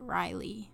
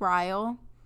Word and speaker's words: Ryle: Um, I also just want to say that um, for the majority of that book, I pronounced it Riley. Is that correct? I think it's Ryle because I think I think I noticed it, like literally in my head.